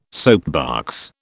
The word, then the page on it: soapbox: British English